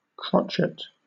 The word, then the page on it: crotchet: British English